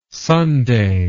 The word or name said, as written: sundae